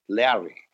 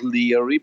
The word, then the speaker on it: Leary: Larry. He was pronouncing in the ancient way, not Leary, but Larry.